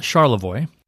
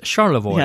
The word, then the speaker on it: Charlevoix: It's called Charlevoix. – Okay, so it's another French It almost sounds like Chamonix, but it's Charlevoix.